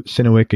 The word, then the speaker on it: Synowiec: Just it's his name, Andrew Sinewick. Again, I'll spell it for you. Get your pen out. All right, good. Sinewick, Synowiec. Andrew Sinewick.